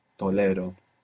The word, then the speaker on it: tolero: But it's important in the present tense to have the accent on the penultimate syllable, tolero.